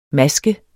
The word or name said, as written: MASKET